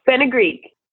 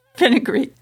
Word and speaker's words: fenugreek: Like fungic, is that how you say that? Did I pronounce that right? – Oh, fenugreek. – Oh, okay. All right then, fenugreek.